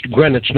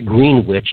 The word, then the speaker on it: Greenwich: Pronounced Greenwich, not Greenwich. Don't ever forget that, because the Brits will get very upset at you if you, blow that one.